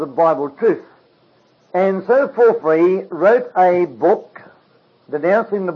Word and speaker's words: Porphyry: I never spelled his name correctly, but it goes something like this, P-O-R-P-H-O-R-Y, something like that, and it's spelled Porphyry. P is pronounced like an F.